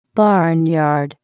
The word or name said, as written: barnyard